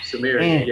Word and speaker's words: Sumerian: It's spelled Smerian. – Sumerian, yeah. – the Sumerian king.